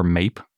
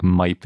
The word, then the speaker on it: Maip: This new megaraptorid is called MIPE or MAPE. I think MIPE is slightly more accurate of a pronunciation. That looks a little bit more like MAPE because it's spelled M-A-I-P.